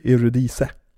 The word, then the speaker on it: Eurydice: Um, men han blev också väldigt inspirerad av myten av Orpheus och uh, jag vet inte om man ska uttala på e-, svenska, men Eurydice tror jag den heter. – Mm. Eur Eurydice kanske. Ja, så borde det vara svenska. Ja, precis. Som är då den här, kan du myten?